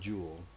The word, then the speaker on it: Duel: Not Duel. – Duel. How do you say Duel? Is there a Y? It's supposed to be a Y sound right after the D, or, you know.